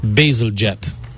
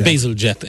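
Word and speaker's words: Bazalgette: kiejtve szépen Basil Jet úgy mondják Basil ezt. Jet. Basil Jet, ez a, ez a helyes kiejtése.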